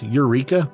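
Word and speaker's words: Yreka: I don't know if this is pronounced Eureka, but it's Y-R-E-K-A. – It is today.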